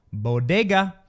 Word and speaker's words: bodega: Jill Biden butchered the pronunciation of bodega. Bodega.